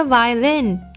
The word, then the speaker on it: violin: 而且呢，它的读音重音是在后面。这是一把什么样的小提琴呢？This is the violin I used to play when I was little。